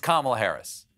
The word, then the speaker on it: Kamala Harris: Okay, that's not a hard name to pronounce. It's Kamala Harris. Get it right next time, Senator David Pubedue.